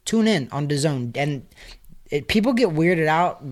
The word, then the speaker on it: DAZN: It's spelled DAZN, but it's pronounced DAZN.